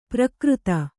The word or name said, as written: ಪ್ರಾಕೃತ